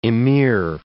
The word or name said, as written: ameer